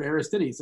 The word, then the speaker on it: Aristides: I heard Aristides a couple of times. So I think that's how you say it correctly.